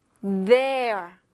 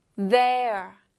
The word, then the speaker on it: there: B-E-T-H-E-R-E. Once again, 한 번 더. B-E-T-H-E-R-E. And the sound, 소리들. B-E-T-H-E-R-E. 자, 그런데 여기 th 붙으면 트흐, 트흐, 트흐 이게 아니라 아예 새로운 소리가 되죠. 트, 트라는 소리가 됩니다. 이거 발음할 때 혀끝이 윗니랑 아랫니 사이로 나왔다가 들어가야 돼요. I'll show you, 보여드릴게요. 트, 트, 그래서 트, 이렇게 b, e 합치면 B, B, T, E, R, E, there, there, there 입니다.